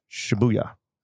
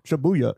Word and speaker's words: Shibuya: Oh, I didn't know if I'd said the neighborhood yet, but then I was pausing because you were putting the emphasis on a different syllable than I thought. – Shibuya. – Oh, okay. – Shibuya.